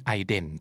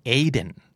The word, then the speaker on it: Aiden: Aiden แต่จริงๆแล้วอเมริกันจะออกเสียงชื่อนี้ว่า Aiden